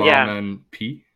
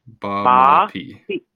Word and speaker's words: Baamaapii: So the word is Baamaapii. So we'll see you again sometime. – Yeah. Later. Baamaapii. Ba. Ba. – Ma. – Ba. Ma. – Ma. P. Ba. – Ba. – Ma. P.